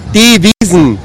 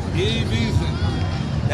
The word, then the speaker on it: Wiesn: Say it again, pronounce it again. – Die Wiesn. – Die Wiesn. That's what Oktoberfest is really called, all you Americans.